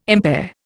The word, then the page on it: impair: Indian